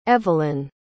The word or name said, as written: evelyn